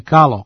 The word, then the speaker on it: Kalo: To su uglavnom pripadnici Kale plemena, rekli bi Finci, ili Kalo, kako se na romskom jeziku izgovara njihovo ime.